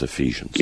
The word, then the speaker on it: Ephesians: That's Ephesians. It's pronounced Ephesians.